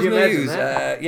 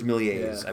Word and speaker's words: Méliès: It's M-E-I-L-E-S, but it's got all sorts of accents over the top of it. George Méliès, I believe, is how it's pronounced.